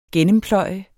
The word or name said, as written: gennempløje